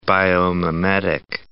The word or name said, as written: biomimetic